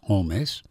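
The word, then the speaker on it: Holmès: The name is spelled as if it were Holmes, but it's Holmes.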